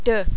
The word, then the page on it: 2: French